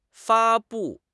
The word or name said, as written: 发布